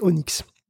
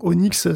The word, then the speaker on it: ONNX: Donc il existe un format qui est une sorte de langue commune d'espéranto, hein, du deep learning, c'est Onyx. Donc Onyx, ça s'écrit O-N-N-X.